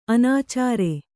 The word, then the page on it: ಅನಾಚಾರಿ: anācāri